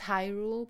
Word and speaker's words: Hyrule: Like, there's speech in this game, so you learn how to properly pronounce stuff. It's Hyrule, by the way.